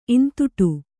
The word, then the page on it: ಈಂದುಟಿ: īnduṭi